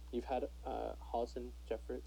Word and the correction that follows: Hollis Jefferson: Sorry, I can't ever say his name. – Hollis Jefferson.